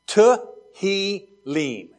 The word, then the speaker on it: Tehillim: Tehillim. Everybody say Tehillim. – Tehillim. – Now, in Hebrew, we tend to accent words on the last syllable, not like English, which is often like on the next to the last syllable.